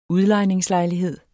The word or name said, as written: udlejningslejlighed